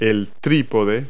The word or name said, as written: tripod